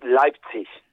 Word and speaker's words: Leipzig: sí, como una CH al final, ¿sabes? El eh, diptongo EI en alemán siempre se pronuncia AI, ¿sabes? Entonces, eh, por eso eh, es Leipzig.